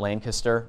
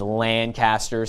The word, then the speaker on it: Lancaster: And when you out there, if you've ever been to Lancaster Baptist Church, not Lancaster, which is around here, it's Lancaster, so you pronounce a little different there.